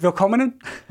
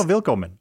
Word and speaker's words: Willkommen: Did I say it right? Willkommen? There it is. – No, Willkommen.